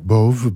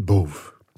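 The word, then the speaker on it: Bove: Emmanuel Bouve, tak, Bouve, bo pisze się przez VE na końcu, to E po francusku się nie czyta w wygłosie, także jest na końcu wyrazu, nie czytamy E, chyba że ma nad sobą akcent, to wtedy by było Bouve. Ale ponieważ nie ma tego akcentu na D, czytamy Bouve.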